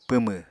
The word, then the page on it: pwmw: Apatani